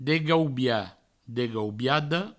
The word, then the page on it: degaubiat, degaubiada: Provençal; degaubia, degaubiado